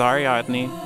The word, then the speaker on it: Ariadne: Ariadne? Is that how you say it? This is Ariadne.